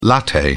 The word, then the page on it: latte: British English